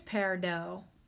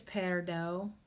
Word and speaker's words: peridot: I think it's peridot. Like, it looks like peridot, but I think it's peridot. Like, it's a silent T.